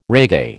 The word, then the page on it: reggae: American English